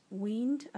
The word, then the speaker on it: Wynd: spelled W-Y-N-D. I did a little Google about that, and somebody on the internet said it should be perhaps pronounced weaned, as in W-E-E-N-D,